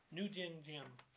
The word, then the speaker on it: Ngo Dinh Diem: New Dinh Diem.